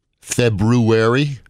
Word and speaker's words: February: It's a lot of people don't pronounce the R. – Right. – February. – Right. – Yeah. – Kind of like library. Library is another word that people don't put the R in.